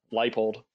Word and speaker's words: Leipold: And then I'll go um, 5% uh, Lance Leopold, Leipold, and then the, the rest of the field, okay.